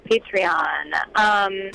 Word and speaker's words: Patreon: Am I saying that right? – Yes, Patreon.